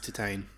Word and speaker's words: Titane: Yeah, it's pronounced Titane. – Oh, well, color me corrected. – I was all the same. I was like, it's Titane. And then now I watch reviewers and they're like, it's pronounced Titane. – Well, fuck you. – It's because it's French, you know? – Oui, yeah.